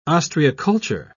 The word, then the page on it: ostreiculture: British English